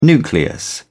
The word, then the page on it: nucleus: British English